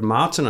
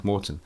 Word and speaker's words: Maurten: What Martin is saying, or Morton, how do you say Morton? – Morton.